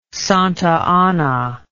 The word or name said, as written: Santa Anna